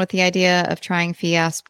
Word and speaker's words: Fiasp: So we tried to get the, how do you say it? The Fiasp? The Fiasp? – Yeah. But they told us that my endocrinologist said that it's a non-preferred drug with our insurance plan.